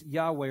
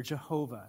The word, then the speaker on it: Yahweh: If you see any time where it's L-O-R-D, lowercase, that's Adonai. When it's capitalized like that, it's Yahweh or Jehovah is another way of pronouncing that.